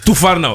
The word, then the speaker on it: Tufarno: Spreek je naam goed uit? Is het Tarifi? – Je zat bijna goed. – Bijna. Turuf. Zeg jij maar even voordat ik mijn tanden breek hier zo. – Tufarno.